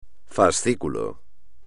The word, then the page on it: fascículo: espagnol